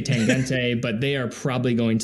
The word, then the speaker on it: Tangente: And this is a classic timepiece, the Tangente Midnight Blue. Now, am I saying that correctly? Is it Tangente? Tangent is probably how I would go with it.